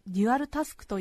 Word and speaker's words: デュアルタスク: した 4 月 20 日放送分安住紳一郎の日曜天国 10 時 33 分までお聞きいただきましたなお 2 つの課題を同時に行うこと専門用語で正しくはデュアルタスク